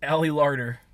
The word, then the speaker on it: Ali Larter: Ali Larter.